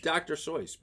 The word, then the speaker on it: Seuss: And it is Dr. Seuss, by the way. That is the correct pronunciation.